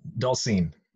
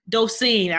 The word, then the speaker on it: Dosine: Did I pronounce the last name correctly? – Dosine. – Dosine. I figured I was like, I was went back and forth. – Yes, like, it's fine. So like Dole and then Scene.